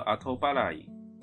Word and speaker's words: 後払い: 接下来第二类型，前面是事前的，那接下来是 auto b a l a y 的 OK， postopay 卡达，呃，这边汉字写作后付，它念作阿托巴拉伊，阿托巴拉伊就是延后付款。那接下来 postopay 哎，蛮容易理解，就是 postpay 就是后付款式。好，这边解释阿托巴拉伊是 kino den shimane des，クレジカードを連携されたタイプが対応的で、ポストペイカード電子マネーのように。